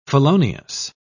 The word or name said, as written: felonious